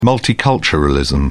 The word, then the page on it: multiculturalism: British English